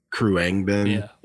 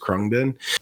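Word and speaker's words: Khruangbin: So like Kruangbin is how most people like initially try to pronounce it, but I think it's just Krungbin.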